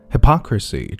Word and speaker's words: hypocrisy: Hypocrisy, 注意重音的位置在后。Hypocrisy. Sir Richard Branson has been accused of hypocrisy after investing in a luxury,